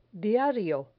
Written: diario

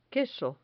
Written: queso

